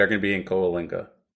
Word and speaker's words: Coalinga: And then Coalinga, is that how you pronounce it? – Yes.